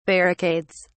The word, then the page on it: barricades: British English